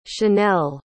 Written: chanell

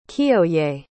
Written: Kiyoye

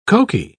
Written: cokie

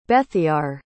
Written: Bethiar

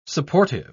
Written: supportive

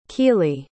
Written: kiele

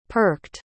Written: perked

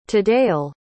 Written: today'll

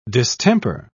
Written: distemper

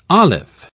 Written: alif

